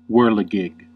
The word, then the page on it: chenille: French